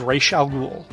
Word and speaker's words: Ra's al Ghul: The Saga of Ra's al Ghul.